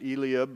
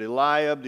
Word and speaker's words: Eliab: And so he went through, and the first one that came up, the oldest son, Eliab, some pronounce it Eliab, but Eliab, the oldest, and he came, and Samuel was mesmerized by just the physical stature of Eliab.